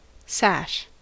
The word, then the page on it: sash: American English